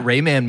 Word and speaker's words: Rayman: Maybe that Rayman movie will be good, huh? – That's my Jack Black move.